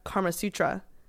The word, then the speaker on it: Kama Sutra: So came home with my mom, and when I went to BC, I saw a Kama Sutra in, did I say that right? – Yep.